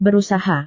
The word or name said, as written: berusaha